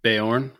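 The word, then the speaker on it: Beorn: burn is that how you pronounce it? – Bayorn. – Bayorn.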